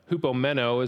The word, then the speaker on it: hupomeno: Hupomeno is how you would pronounce it.